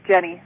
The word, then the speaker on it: Jenny: My name is Jenny. Starts with a J. – Oh, spell it for me. – J-E-N-N-Y.